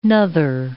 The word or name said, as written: nother